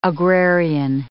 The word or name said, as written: agrarian